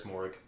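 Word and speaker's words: Moehrig: I have them taking Trayvon Morrig. Merrig, I don't know how to say it. – I'm pretty sure it's Morrig. – I've heard people say both.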